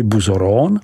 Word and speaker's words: Bouzeron: Naší první zastávkou musí být vinařská obec Bouzeron, vyslovuj Buzoron, která je společně s obcí Chassey-le-Camp, vyslovuj Chassey-le-Com, jedinou oficiální apelací pro přípravu vín z bílé odrůdy Aligoté.